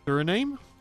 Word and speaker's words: Suriname: Other countries on the list include the Dominican Republic, Egypt, Haiti, Iraq, Madagascar, Nicaragua, Philippines, Senegal, and Suriname.